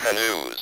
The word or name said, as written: canoes